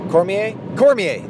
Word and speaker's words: Cormier: I can't say his name ever. Not ever. Cormier, that's it.